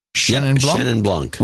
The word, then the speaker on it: Chenin Blanc: And I had a Chenin Blanc. Am I pronouncing that right? – Chenin Blanc.